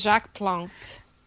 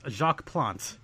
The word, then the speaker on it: Jacques Plante: I said, who is Jacques Plante? – Wow, with the pronunciation. That is correct. Jacques Plante.